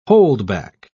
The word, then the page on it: holdback: British English